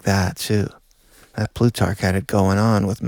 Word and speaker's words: Plutarch: There's a collection of proverbs attributed to Plutarch. – Plutarch. – Plutarch. – I believe is how it's said, yeah.